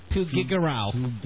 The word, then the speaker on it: Tuguegarao: T-U-G-E-G-E-R-A-O. – Off with your clothing. – You pronounced it wrong. – No, it's Tuguegarao. – It's Tuguegarao.